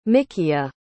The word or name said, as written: mikia